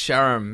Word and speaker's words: Sharam: uh, and I think I'm going to go with oh, do I, uh, either no Max Sharam maybe because even Zombie has some stuff going for it.